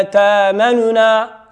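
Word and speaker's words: تأمنا: تتولي لا تأمننا لا تأمننا لا تأمننا، لا تنطق النطق هنا لازم وواجب أن تنطق بحركة الضمة ولكن تسرع وتخفت عندها وتخفض صوتك.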